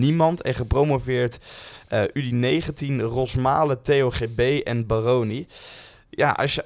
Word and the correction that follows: Baroni: Eh, Baroni, denk ik. – Baroni, Baroni, Baroni, ja. Sorry, de klemtoon.